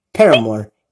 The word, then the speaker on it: Paramore: All right, here's Petamore into you. Paramore, Petamore, I can't say the name right. Forgive me, band. Paramore, into you on the show right here on Spotify.